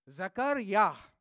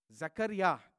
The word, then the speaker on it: Zechariah: Zechariah. Zechariah. That is the right way of pronouncing it in Hebrew.